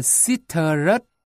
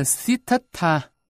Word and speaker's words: Siddhartha: Đúng ra, cái tên ấy phải được đọc theo tiếng Hindi là Siddharth, Siddharth. Còn phiên âm từ tiếng Sanskrit và Bali là Siddhartha, đọc là Siddhartha.